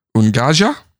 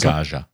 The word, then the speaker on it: Unguja: So, the two larger islands are called Ungaja, U-N-G-U-J-A. – Okay. – So, however you want to pronounce Ungaja.